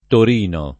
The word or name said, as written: Torino